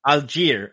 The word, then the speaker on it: Allgeier: ¿Es Allgeier o Allgeier? – Allgeier, ok.